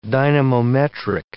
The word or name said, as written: dynamometric